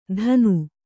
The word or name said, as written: ಥಾನು